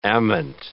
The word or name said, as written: ament